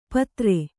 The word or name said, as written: ಪತ್ರೆ